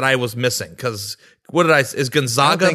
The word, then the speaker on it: Gonzaga: And I'm thinking to myself, we can't view Gonzaga as Gonzaga. We're going to teach you how to say it before they win the national title.